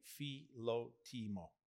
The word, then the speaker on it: Philotimo: I mean, we got to get a pronunciation check on this first before we even get into that, I think so. – In a simple way, Philotimo. So if you say it quickly, Philotimo, yeah.